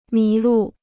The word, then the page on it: 迷路: mí lù